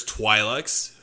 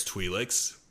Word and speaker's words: Twi'leks: While the there's like multiple ways you could pronounce Twi'leks. There's Twi'leks and there's Twi'leks. But from what I've heard, both of them are correct, basically.